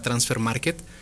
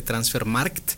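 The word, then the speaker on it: Transfermarkt: Trabajo en una empresa que se llama Transfer Market, o se pronuncia realmente Transfer Markt, porque es una empresa alemana con 25 años de trayectoria.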